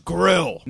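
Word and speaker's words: Grille: My last name is Grill, not spelled like the apparatus.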